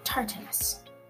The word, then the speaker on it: Tartarus: It's called Tartarus. Not the sauce you put on fish, and I know I sometimes pronounce it wrong.